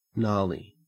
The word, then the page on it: gnarly: Australian English